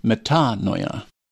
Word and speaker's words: metanoia: metanoia, the accent being on the second syllable.